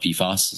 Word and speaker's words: PFAS: I guess it's called PFAS.